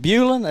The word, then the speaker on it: Beulah: Is it Beulah? Is it? – Well, it's Beulah if we pronounce it. It's Beulah if anybody else does.